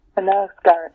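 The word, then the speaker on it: Knausgård: Well, there's Rachel Cusk, C-U-S-K. – Yes. – Carl Knowles? – Yes. – I don't know how to pronounce his name. He's a Norwegian. – Karlov Knausgard. – Knausgard.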